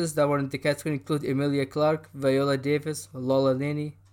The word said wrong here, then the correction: Laura Linney: Laura Linney. I mispronounced her name.